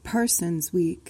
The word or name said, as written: parsons week